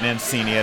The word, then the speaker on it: Mancini: Sure, you know, I took high school Italian back in like 1982, 83, because my last name is Mancini, and I pronounce it Mancini. I don't pronounce it right.